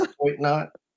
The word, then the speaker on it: toit: I'm going to say it. It sounds ridiculous. Toit knot. That's how they say it. T-O-I-T, toit knot.